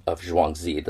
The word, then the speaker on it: Zhuangzi: So I also want to read a beautiful little excerpt from the book of Zhuangzi. That's the correct Chinese pronunciation I've learned from YouTube videos.